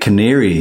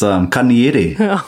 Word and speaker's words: Kanieri: One of the big ones is Lake Canary. Have you guys heard of that one? – No. – Lake what Canary. It's Kanieri.